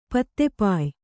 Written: ಪತ್ತೆಪಾಯ್

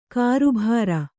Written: ಕರ್ಬುರ

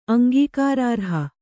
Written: ಅಂಗೀಕಾರಾರ್ಹ

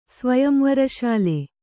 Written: ಸ್ವಯಂವರಶಾಲೆ

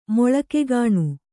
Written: ಮೊಳಕೆಗಾಣು

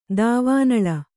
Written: ದೇವನಾಲ

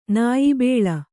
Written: ನಾಯ್ಬೇಲ

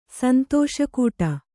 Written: ಸಂತೋಷಕೂಟ

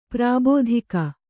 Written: ಪ್ರಾಭಾತಿಕ

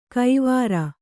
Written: ಕೈವಾರ್

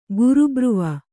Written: ಗುರುಬ್ರುವ

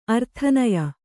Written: ಆರಾಧನೀಯ

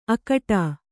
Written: ಅಕಟಾ

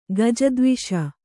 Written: ಗಜದ್ವಿಷ

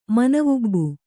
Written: ಮನವುಬ್ಬು

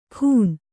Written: ಖೂನ

